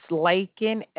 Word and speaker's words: Laken: It's Laken, L-A-K-E-N. – L-A-K-E-N.